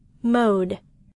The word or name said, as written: mode